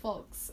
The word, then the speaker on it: folks: Why do you pronounce the L? – The letter L is in the word folks.